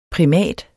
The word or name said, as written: PRIMATEN